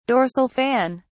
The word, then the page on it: dorsal fin: British English